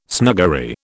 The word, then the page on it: snuggery: British English